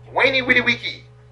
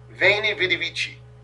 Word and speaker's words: Veni Vidi Vici: Um, and he had said, Wainy Witty Wiki, right? Which is how he would have pronounced it. But by this time, they're pronouncing it more Italian. So it's Veni Vidi Vici, which means I came, I saw, I conquered, right?